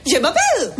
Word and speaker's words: Je m'appelle: Je m'appelle.